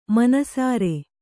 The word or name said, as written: ಮನಸರಿ